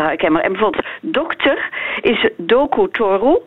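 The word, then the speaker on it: Doku Toru (ドクター): En dat zegt ook iets over de manier waarop het Japans eh, eh, in elkaar zit. iedere lettergreep in het Japans is altijd een open lettergreep. Dus het is altijd een klinker of een combinatie medeklinker-klinker. Okay, dus dokter wordt doku toru.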